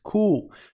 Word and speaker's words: cool: Cool. Presta atenção na pronúncia. Americanos, eles enfatizam muito esse L no final. Então, eles falam cool. Cool. E tem uma abertura em um L.